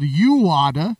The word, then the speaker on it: UADA: I screwed up and have been mispronouncing it the whole time. The band is actually called UADA. That's UADA.